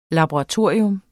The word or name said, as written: LABORATORIE